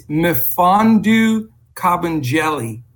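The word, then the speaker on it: Mfiondu Kabengele: But it's Mifondu Cabangeli is the way it's pronounced, and or pretty close to that.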